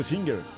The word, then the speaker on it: Singer: Sí, pero yo creo que la época era la Singer. – Y la pronunciación oficial debía ser Singer. – Sí, claro. – Sí. Sí, es que sí era. Sí. Pero le decíamos Singer siempre en los compañeritos.